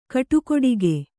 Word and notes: ಕಟಗಡಕಿ: kaṭagaḍagi

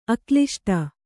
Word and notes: ಅಕ್ಲಿಷ್ಟ: Kannada

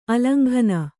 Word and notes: ಆಲಿಂಗನ: āliŋgana

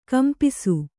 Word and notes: ಕಂಪಾಸು: kampāsu

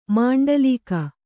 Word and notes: ಮಂಡಲೀಕ: maṇḍalīka

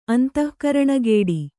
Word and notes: ಅಂತಃಕರಣಗೇಡಿ: Kannada

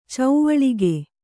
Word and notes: ಚೌವಳಿಗೆ: cauvaḷige

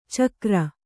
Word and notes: ಛಿಕ್ಕಾರ: chikkāra

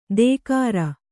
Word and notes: ದೇಕಾರ: dēkāra

